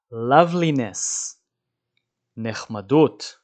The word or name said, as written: loveliness